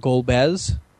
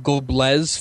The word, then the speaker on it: Golbez: You know, there's like Golbez was the main bad guy. – Okay. – But I've been calling him Goblez for pretty much my entire life.